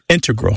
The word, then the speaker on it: integral: But the mathematicians and math using scientists are more likely to say integral with the stress on the first syllable.